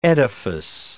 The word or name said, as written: edifice